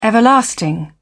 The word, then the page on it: everlasting: British English